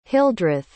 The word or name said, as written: hildreth